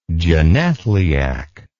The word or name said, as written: genethliac